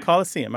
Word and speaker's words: Coliseum: There aren't many anglicized or nativized words that end with S-E-U-M. The only one I can think of mm-hmm. is C-O-L-I-S-E-U-M. How would you say that? – The Colosseum. – Colosseum. All right. So you would pronounce the N like most people say museum.